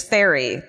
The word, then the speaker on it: Fieri: The correct pronunciation is Ferry, but he says it's Fetty.